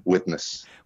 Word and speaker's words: Widnes: Is Widnes? – Widnes.